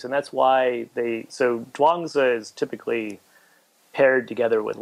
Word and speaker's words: Zhuangzi: Zhuangzi.